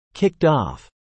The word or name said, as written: kicked off